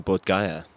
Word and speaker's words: Bodh Gaya: What happened in Bodh Gaya? If I'm saying that correctly, I probably am not. – Yeah, you are. – All right, great. I'll take it. – No, you are.